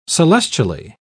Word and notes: celestially: British English

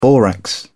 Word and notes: borax: British English

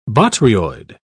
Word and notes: botryoid: British English

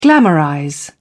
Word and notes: glamorize: British English

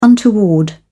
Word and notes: untoward: British English

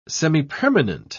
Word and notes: semipermanent: British English

